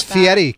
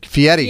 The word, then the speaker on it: Fieri: He did give and it's Fieri, Kennedy.